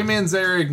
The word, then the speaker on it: Manzarek: It's true, Ray Manzarek never fell into the disco stuff, and he was the one that was...